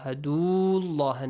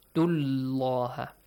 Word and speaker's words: Abdullah: Directly join the dal to lam. An i'budullah. Dullah. No. Abdullah.